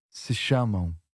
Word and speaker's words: chamam: So, it's not se chama anymore because it's not singular but plural. So, se chamam, se chamam. – Se chamam. – That was a good pronunciation. Chamam. – Chamam. – Yep. – So very similar to se chama in the singular, but a bit more of a nasal ending to the verb, is it? – That's right.